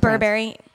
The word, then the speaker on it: Burberry: Am I saying it wrong? – No, I was just putting a different. – Okay. I don't know how it's Burberry. – Pronounced. Burberry. Okay.